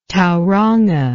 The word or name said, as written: Tauranga